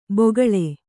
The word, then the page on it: ಬೊಗಳೆ: bogaḷe